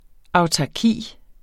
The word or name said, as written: autarki